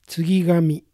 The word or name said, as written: Tsugigami